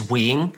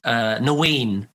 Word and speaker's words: Nguyen: Yeah, so my surname in Vietnamese, or how you're supposed to pronounce it, is Nguyen. But as a kid, I've always pronounced it as Nguyen, right? Just because it was like easier. There's no N in pronouncing it in Vietnamese, but, you know, teachers would ask me and stuff.